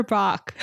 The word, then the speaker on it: Bach: Every every time we listen to like the classical station and they're like by Bach, I like always make fun of them for it, but it is technically the correct pronunciation.